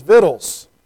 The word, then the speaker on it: victuals: The good southern pronunciation for that word is victuals, right? I'm trying very hard to enunciate and put all the vowels in there.